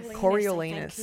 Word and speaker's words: Coriolanus: So, Coriolanus? Coriolanus? How do I say it? – Coriolanus.